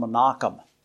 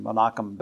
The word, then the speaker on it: Menachem: Manaen was the son of another man also known as Manaen, or the modern name Manaen is actually pronounced Menachem, like in Menachem Begin, the leader of Israel recently.